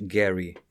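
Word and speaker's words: Gerry: Que não é bem Jerry, isto é uma história curiosa. Eles, o nome dele é Elbridge Gary, pronuncia-se Gary. Uh, mas pronto, com o passar do tempo ficou Jerry, vamos em frente.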